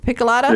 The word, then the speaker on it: Picolata: Is that the right way to pronounce it, Picolata? – Yeah, that's the Picolata Road.